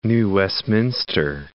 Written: New Westminster

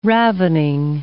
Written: ravening

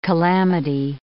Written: calamity

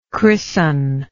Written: christener

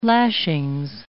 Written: lashings

